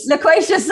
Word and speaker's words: loquacious: For our part, we're absolutely, positively, definitely, completely unwavering in our determination to be a little less loquacious. I don't even know if I pronounced that right. – Loquacious, sorry. I did not pronounce it right.